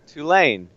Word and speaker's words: Tulane: How do you say Tulane? – Tulane. You can do this. Tulane. – Tulane. – Like Tulane Highway.